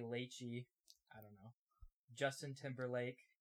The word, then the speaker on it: Lachey: It's Lachey.